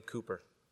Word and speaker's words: Cowper: Cooper, spelled like Cowper, but pronounced Cooper.